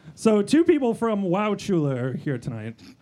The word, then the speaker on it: Wauchula: We are going to Wauchula. – That is not how you pronounce that. – So has anyone been to Wauchula? – It's pronounced Wauchula.